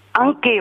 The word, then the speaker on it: Enke: Prononcez-vous? – Enke, on prononce. – Enke? – Oui. – Enke, on écrit E-N-K-E, accent aigu.